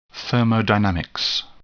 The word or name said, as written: thermodynamics